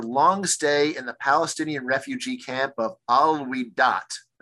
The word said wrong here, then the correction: Al-Wahdat: Am I saying that correctly? – Wahdat. – Al-Wahdat.